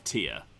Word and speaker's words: Tyr: Cypher of Tyr. T-Y-R.